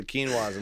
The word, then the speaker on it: quinoa: Do you guys have quinoa? And he's like, oh, the quinoa's in the back.